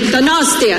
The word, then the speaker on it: Donostia: Donostia. I'm trying. – Venga. – Es que recasco, gosh. Okay. I'm all right. Es que recasco, gosh. How do I say it? Donostia. – Donostia.